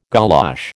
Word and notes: golosh: American English

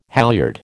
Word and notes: halyard: American English

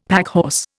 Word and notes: packhorse: Indian

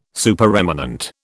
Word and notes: Supereminent: British English

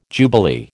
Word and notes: jubilee: American English

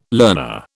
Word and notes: learner: British English

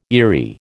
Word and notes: eerie: American English

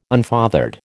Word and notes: unfathered: American English

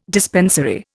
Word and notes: dispensary: Indian

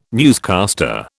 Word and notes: newscaster: British English

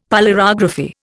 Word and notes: polarography: Indian

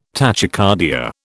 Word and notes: tachycardia: British English

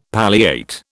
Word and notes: palliate: British English

